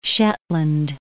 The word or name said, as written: Shetland